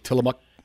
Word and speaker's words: Tillamook: The locals say it like Tillamook. – Tillamook.